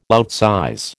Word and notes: outsize: American English

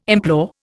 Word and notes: implore: Indian

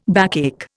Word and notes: backache: Indian

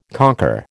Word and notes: conquer: American English

